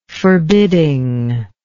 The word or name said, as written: forbiddingness